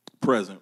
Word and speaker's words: present: Let's take a look at the word present real quick. Or present. Present. Present. All depends where the syllable is. Right? Put the emphasis on the wrong syllable and it means something else.